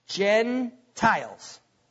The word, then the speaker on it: Gentiles: Because here's a short E, long I. Gentiles. That's how you pronounce that word.